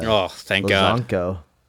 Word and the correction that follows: Lazkano: Lazkano. Sorry, Olier Lazank Lazkano. Nailed nice.